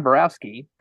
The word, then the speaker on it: Borowski: I'm your host, and I am very, very, very excited today to have John Borowski.